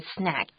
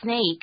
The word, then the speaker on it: snack: 我超喜欢吃零食的。And be careful when you say this word snack. S-N-A-C-K. Do not pronounce it as snake, because that's 蛇的意思。